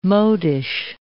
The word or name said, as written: modish